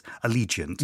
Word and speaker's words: Allegiant: I mispronounced that when I was going to go for Elegiant, but the Divergent series, Allegiant.